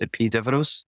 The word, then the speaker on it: Epidaurus: When I was in Greece in 1987, I went to the major healing sanctuary from ancient times, which is spelled Epidaurus in English. It's pronounced Epidevros.